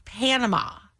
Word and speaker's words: Panama: No, you said it wrong again. – Panama. – Okay, it's Panama.